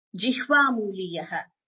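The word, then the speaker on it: jihvamuliya: When a visarga, that is, you know what a visarga is, I just told you, is followed by ka or kha, then we get a pronunciation called Jihvamooliya. For example, here if you see antahkaranam, so antahkaranam, you get that the entire visarga is not pronounced when it is followed by a ka or a kha.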